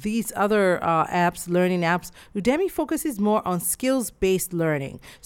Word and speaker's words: Udemy: Next on my list is Udemy. Udemy is spelled U-D-E-M-Y, and it's a learning platform that allows you to purchase and take courses on various subjects.